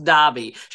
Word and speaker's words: Derby: I might just note for your listeners, it's spelt Derby, it's pronounced Derby. She would like to have that set straight on the record.